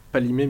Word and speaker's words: Kpalimé: La première partie de notre séjour était dans une ville qui s'appelle Palimé, mais qui s'écrit Kpalimé.